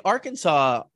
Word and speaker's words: Arkansas: I mean, Arkansas sounds better. It kind of rolls off the tongue, but it's weird because you pronounce Kansas, Kansas. – Right. – There's no Kansas when you say Arkansas.